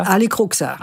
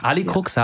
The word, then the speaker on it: Alicruxa: Die Chefin fliegt nach Istanbul und lässt ihre Ziehtochter, wie wird die ausgesprochen, Alicruxa? – Alicruxa. – Alicruxa, ja.